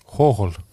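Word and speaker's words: Hohol (Гоголь): ma küsiks, mismoodi Gogolit äh, ukrainapäraselt hääldada? Hohol.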